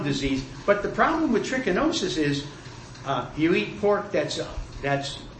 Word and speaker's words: trichinosis: Could trichinosis, am I saying it right? – You're saying it right.